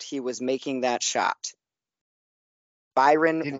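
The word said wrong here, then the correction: Bryon: Russell. Boom. It's there. – Brian. – Brian. – Brian. – You said it again. – I did say it wrong again.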